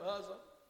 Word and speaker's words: Uzzah: Uzzah, an A-ho, A-ho, A-ho, how do you pronounce his name? Uzzah.